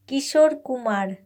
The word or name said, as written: Kishore Kumar